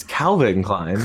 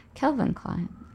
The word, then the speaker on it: Calvin Klein: You pronounce Calvin as Kelvin? Wait, what's the name of the comic with the boy and his tiger? – Calvin and Hobbes. – Yeah, not Kelvin and Hobbes.